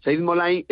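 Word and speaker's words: Saeid Mollaei: Como es Said Molay, creo que lo dije bien, ¿no? – Sí, lo has dicho perfectamente. Said Molay es campeón del mundo, subcampeón olímpico, es un judoka iraní.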